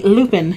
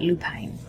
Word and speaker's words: lupine: And it's in the Lupin colorway. – I would call that Lupine. – Or Lupine. It's L-U-P-I-N-E. – Okay. – I can never remember which way it's pronounced.